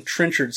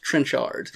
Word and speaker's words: Trenchard: Now, I would have, before yesterday, I would have pronounced that Brian Trenchard Smith. But after speaking with him and his very proper English accent, I know that now that it is pronounced Trenchard.